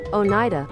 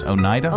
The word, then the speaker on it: Oneida: It's Oneida. – Oneida. It's Oneida? – Oneida.